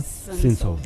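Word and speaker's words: Cinsault: Cinzeau.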